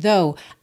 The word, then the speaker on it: though: You must understand, though. Aqui, understand termina em D. Though, essa palavrinha T-H-O-G-H, tem uma pronúncia mais simples do que parece, né? Até porque o final, esse G-H, não é pronunciado.